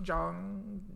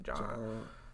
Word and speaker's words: Jeong: Jeong Jeong.